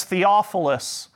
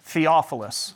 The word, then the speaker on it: Theophilus: And his name is Theophilus, if you're afraid of pronouncing it. Theophilus.